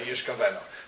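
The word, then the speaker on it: yishkaveno (ישגלנה): No, in this word you pronounce yud, shin, gimel, lamed, nun, hey, as though it was written, yud, shin, chof, beis, nun, hey, yishkaveno.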